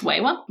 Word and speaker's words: Wiwa: And on the note of pronunciation, I also want to mention that there are two possible pronunciations for the name Wiwa. Attempts made by anthropologists at the time when they were alive transcribed their name pretty consistently as though it was pronounced Wewa, but modern Zuni people generally say Wiwa, and so I've gone with that pronunciation rather than trying to decide whether I think the anthropologists were right or not.